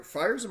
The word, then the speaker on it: Fiers: So I took it a little bit out of context, but basically he got out there and he said that Fiers, am I saying his name right? – Yeah.